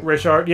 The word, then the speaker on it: Rayshard: Yeah, I mean, the officer got assaulted while trying to arrest, you know, Rayshon, or is that how you say his name? – Rayshard, I think. – Rayshard, yeah.